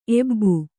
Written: ಎಬ್ಬು

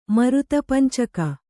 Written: ಮರುತಪಂಚಕ

